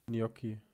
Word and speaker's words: Gnocchi: Gnocchi. Gnocchi. Also, dass du quasi nach dem N in J, also du, das spricht, das, also das spricht man ganz anders, als man schreibt.